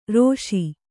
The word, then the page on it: ರೋಷಿ: rōṣi